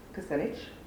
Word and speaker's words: Kucinich: He has served as a press secretary for Dennis Kucinich. Did I pronounce his name right? – Yep. – Good. I always mispronounce that.